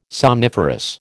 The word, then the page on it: somniferous: American English